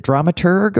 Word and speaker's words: dramaturg: Would you rather if it's the person, is it dramaturg or dramaturge? – Dramaturg. – Hard G. Okay.